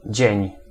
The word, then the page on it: dzień: [d͡ʑɛ̝̃ɲ]; /ʒ́ė̃ń/